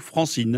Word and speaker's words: Francine: France, première syllabe, franc, et farine, dernière syllabe, in, donc Francine. – Contracté, on arrive à Francine.